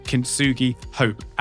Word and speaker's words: Kintsugi Hope: And I hope I said that right, because I said it to her earlier. Sometimes I get that word wrong and I really shouldn't. Julie Wilson, am I correct? – You are correct.